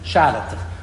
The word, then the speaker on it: Chartres: Also so sagt man so schon koidisch, aber bei ist ein Platz in Frankreich, wo es in der goische Spelling ist es C-H-A-R-T-R-E-S, aber der Weg, wie man pronounced ist, Scharatich. Also wie man pronounced ist in Französisch.